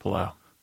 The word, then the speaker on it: Palau: is it pronounced Palau? – The Republic of Palau.